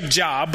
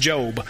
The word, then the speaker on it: Job: It looks looks like Job, but it's pronounced Job.